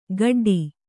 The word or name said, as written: ಖಡ್ಡಿ